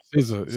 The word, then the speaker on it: SZA: SZA, yeah.